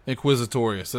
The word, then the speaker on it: Inquisitorius: Inquisitorius.